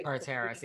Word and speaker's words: Carteris: But Andrea Zuckerman, as we all know her, her real name is Gabrielle Carteris. That's how you pronounce it, right? – Carteris, yeah.